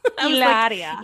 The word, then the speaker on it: Hilaria: But before that, we were just chatting about and I don't even know how to pronounce her name anymore, but like Hillary Baldwin situation. – Hilaria. – Hilaria? – Hilaria. Hillary. What?